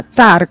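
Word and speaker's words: Tár: La película se llama Tar, con acento en la A, que es el apellido de la protagonista, a quien interpreta a Cate Blanchett, que es Lydia Tar.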